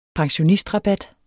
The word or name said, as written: pensionistrabat